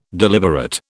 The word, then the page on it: deliberate: British English